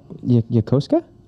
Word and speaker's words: Yokosuka: Yokosuka? – Oh!